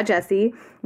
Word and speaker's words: Gyasi: And it is called Homegoing by Ya Jesse, which is spelled G-Y-A-S-I.